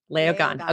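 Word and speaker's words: Léogâne: Leogon.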